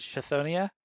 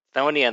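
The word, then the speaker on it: Chthonia: No, there is no ch. There is a Chithonia somewhere on the intertubes, but Thonia in the book is uh, just Thonia.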